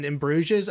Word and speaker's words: Bruges: I was trying to even pick out like his In Bruges role, where he plays an ass clown in Bruges, and right.